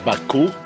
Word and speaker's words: Baku: His name is Baku, and we can see Baku. – The name is phonetically engraved. The crane and the basket stand for the sounds bak, and the chick stands for the sound u.